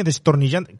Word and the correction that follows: desternillante: ¿cómo se dice? Desternillante, desternillante, yo siempre destornillado, ¿no?